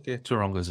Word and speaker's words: Taronga: Taronga Zoo. Did I say it right? – Yeah, Taronga Zoo.